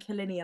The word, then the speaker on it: Killiney: No, sorry, I think it's actually Killeany or Killiney. Hang on. One second. – Has to be one of those three anyway, Killiney. – Pronunciation. – It's Killiney. I was saying Killiney and I was like, that doesn't seem right.